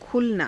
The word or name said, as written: khulanaa